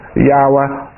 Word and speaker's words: Yahweh: Is he going to say, well, Jehovah? Well, wait a minute, Moses. Let's get it, pronounce it right. Say it the way you're supposed to. Yahweh.